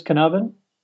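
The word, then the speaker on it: Caernarfon: Are you sure it says Carnarvon? – Yeah. – Okay. – A single F in Welsh is a V sound. A double F is a soft F. – Okay. – It's pronounced Carnarvon.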